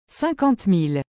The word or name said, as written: 50000